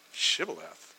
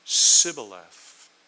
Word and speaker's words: Shibboleth: Shibboleth. And he would say, "Sibboleth." For he could not pronounce it right, because the Ephraimite language does not have the sh sound.